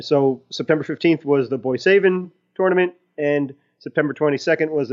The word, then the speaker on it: Boissevain: So on September 15th and September 22nd in their local arenas, Boissevain, I believe, is how you pronounce the uh, the city, which I did look up, by the way.